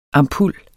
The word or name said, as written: ampul